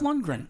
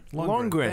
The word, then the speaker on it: Lundgren: Did you call him Lundegaard? – He did. Yeah. Lundegaard. – Well, how do you say his last name? Lundgren? Lundegaard? I don't know. – Dolph Lundgren. – Lundgren. Thank you.